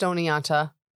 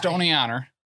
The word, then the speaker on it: Oneonta: Especially we don't pronounce the T, so it's even more confusing. – Yeah. West Oneonta. – West Oneonta, New York.